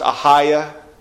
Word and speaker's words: Achaia: And the other town there is pronounced Achaia.